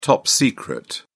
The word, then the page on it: top secret: British English